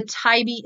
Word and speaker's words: TIOBE: And I'm going to encourage you to go to and look at the TIBE index.